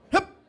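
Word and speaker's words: Höfn: Because, you know, if anyone doesn't know, it's spelled H-O-F-N. And it's pronounced... Hop!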